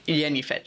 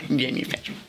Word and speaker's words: Jennifer: Jennifer. We don't pronounce the J. We pronounce it Y. Jennifer.